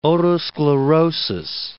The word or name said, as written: otosclerosis